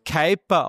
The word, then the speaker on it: Kuiper: Aber tatsächlich wird es Kuiper ausgesprochen. Vielleicht kennt ihr von euch das Lied Hyper Hyper, zwar Techno-Nummer. Genau wie Hyper, genau so spricht man Kuiper aus.